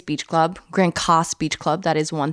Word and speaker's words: Grand Case: The city that we stay by is called Grand Cas, but it's spelled like case, and they do correct you on that one.